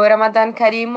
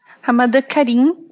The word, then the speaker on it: Ramadan Karim: É Ramadã Karim? Kerim? – É, tenho. Pode falar. É, o Ramadan Karim ou Mubarak, tanto faz. – Ramadan Karim, para vocês, espero ter pronunciado certo.